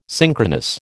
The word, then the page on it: synchronous: American English